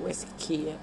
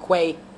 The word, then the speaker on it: West Quay: I think that's how you say it, West Quay. I remember when I was younger, I used to call it Quay. – Yeah, like Sammy Quays. It's West Quay.